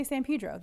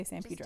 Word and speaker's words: San Pedro: Just say San Pedro. That's it. Mm-hmm. Don't ask any questions. Just say San Pedro. – Just do it. – The long E.